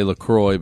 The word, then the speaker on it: LaCroix: I say LaCroix because the website says rhymes with enjoy.